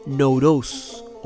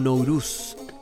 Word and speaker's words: Nowruz: que en su idioma original, en el kurdo, se pronuncia Nowruz o Nowruz.